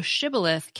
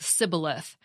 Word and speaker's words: shibboleth: The Ephraimites couldn't pronounce the SH sound, so shibboleth came out sounding wrong, like sibboleth, making them instantly identifiable as they were trying to cross enemy lines.